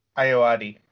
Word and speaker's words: Ayoade: Richard Ayoade? – Ayoade. – Ayoade, and Clancy Brown.